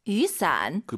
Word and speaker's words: 雨伞: 우산 두 글자로 雨伞. – 그 비오다 할 때 YU 발음이요. 예, 위 아니고 유.